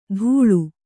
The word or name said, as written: ಧೂಳು